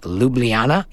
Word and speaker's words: Ljubljana: I think I've got the city right Ljubljana in Slovenia. Yep. yep. But the location? Cankarjev, Dom. Okay, it's would, very well known. It's like Hollywood Bowl of, of Ljubljana.